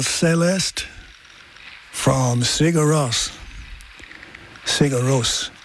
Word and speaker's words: Sigur Rós: you don't just say Puccini on here, you know. You give it loads. So it's Sigur Rós. Sigur Rós.